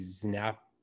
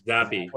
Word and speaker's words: Zappe: I took Western Kentucky largely because, you know, I thought they lost their quarterback, Bailey Zapp, Zappe.